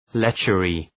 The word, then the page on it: lechery: ‘letʃərı